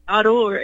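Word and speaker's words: Owuor: His name is Dr. David Odor, is how you say it. It's O-W-U-O-R.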